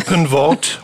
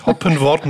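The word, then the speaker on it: Hoppenworth: Ja, Hoppenwort, Hoppenwort mit Doppel-P und Ploch.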